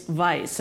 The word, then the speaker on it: Weisz: It's Weisz. Apparently, it is a Hungarian name or of Hungarian origin, so it's Rachel Weisz.